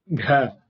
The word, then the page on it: gh: Sanskrit